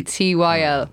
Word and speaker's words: pterodactyl: It's pterodactyl.